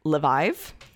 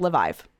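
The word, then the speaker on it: Leviev: Yes, aka Simon Levive. I've heard him pronounce it two different ways, which on brand for him. I've heard Levive and I've heard Leviv, but okay. – I'm going with Levive.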